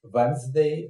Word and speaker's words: Wednesday: А среда – это Wednesday, но э, те, кто знает, как это пишется, через D оно пишется, да, то есть как бы Wednesday.